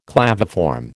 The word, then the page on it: claviform: American English